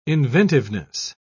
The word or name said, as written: inventiveness